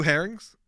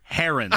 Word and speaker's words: heron: There's probably some blue herrings. – Herons.